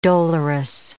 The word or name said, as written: dolorous